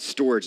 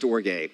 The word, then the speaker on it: storge: I called it storage the first time I looked at it, but it's actually pronounced storge.